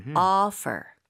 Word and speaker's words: offer: 네, F 발음 강하게 해 주시고 offer. offer. – 네, 우리가 이제 한국에서는 오퍼 들어왔다, 오퍼를 한다, 오퍼라고 하는데 그게 아니라 O 부분을 입 모양을 조금 더 벌리는 거. 그리고 당연히 F라고 하는 것은 입술이 붙으면 안 됩니다.